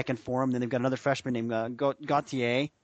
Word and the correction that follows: Gautier: Gautier, I'm sorry, that's how you say it, who Rolando Gautier, he's an impact guy.